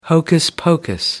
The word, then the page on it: hocus pocus: American English